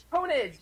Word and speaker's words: pwnage: What does P-W-N-A-G-E mean? Ponage or punage? – Ponage!